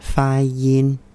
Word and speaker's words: 发音: I think it's appropriate that this word has two first tones, like you're singing it. Fa yin.